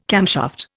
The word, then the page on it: camshaft: Indian